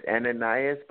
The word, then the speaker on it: Ananias: and it's so obvious that it's Ananias, am I pronouncing it right? Is the one that God sent to Saul to help him with his blindness. – Amen. His name is Ananias, but I will accept that.